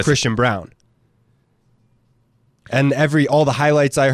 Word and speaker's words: Braun: So what do you think about Braun? Are you saying Brown or Braun? – I believe it's Brown. It's spelled like Braun, I agree.